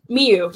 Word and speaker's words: Miyu: Mayu Watanabe, I think. I can't pronounce her name. – Miyu Watanabe? – Miyu, yeah.